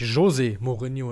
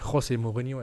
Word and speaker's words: José Mourinho: ist natürlich José Mourinho und nicht José Mourinho, er ist ja Portugiese und kein Spanier.